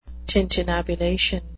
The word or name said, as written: tintinnabulation